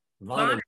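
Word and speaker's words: Wannenwetsch: I think it sounds like a V, actually. Vonnenwetsch.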